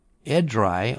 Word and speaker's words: Edrei: E-D-R-E-I, Edrei, I guess you pronounce it.